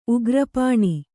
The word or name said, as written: ಉಗ್ರಪಾಣಿ